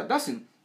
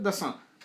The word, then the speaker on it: Dassin: pero en Estados Unidos se pronuncia Dassin. Tipo. Julius Dassin o Jules Dassin y ya llegó a Francia, puta, y la weá se, se, se, veía francés, así que el mundo empezó, eh, toda la gente empezó a pronunciar Dassin.